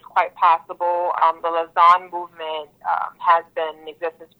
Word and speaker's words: Lausanne: Now, is this pronounced Lusane? Lusane? L-A-U-S-A-N-N-E? Is it Lusane? – Oh, Lausanne. – Lausanne. Okay, Lausanne.